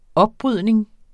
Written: opbrydning

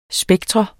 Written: spektrum

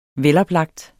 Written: veloplagt

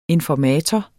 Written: informator

